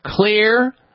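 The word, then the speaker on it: Xlear: You need to keep your nasal cavity clear, clean, and moisturized with a nasal spray that I use on a regular basis, Clear Rescue Nasal Spray. And Clear is spelled with an X, X-L-E-A-R, Clear Rescue Nasal Spray.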